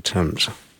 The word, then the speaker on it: Thames: The Thames. I believe it's the Thames.